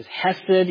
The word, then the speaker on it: hesed: It's a word in Hebrew that is pronounced as hesed.